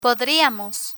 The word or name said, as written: podriamos